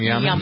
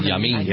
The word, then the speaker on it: Yamin: Is that how you pronounce his name, Yarman? – Yamin. – Yamin. Is it Yamin? – Mm-hmm.